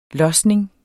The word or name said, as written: losning